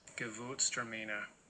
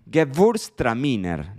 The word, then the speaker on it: Gewürztraminer: Gewürztraminer. Gewürztraminer. Geburstraminer, ¿no? yo siempre la pronunciaba como Geburstraminer, porque esa W, pero Geburstraminer.